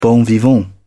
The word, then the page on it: bon vivant: British English